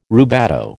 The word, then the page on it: rubato: American English